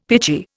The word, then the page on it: pitchy: Indian